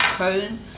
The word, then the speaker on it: Köln: So that's the city is Cologne, but in German it's Köln.